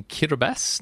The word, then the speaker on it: Kiribati: We are tonight in Kiribati. Now, Kiribati, it's actually spelt Kiribati, but the T-I is pronounced S, and it's officially the Republic of Kiribati.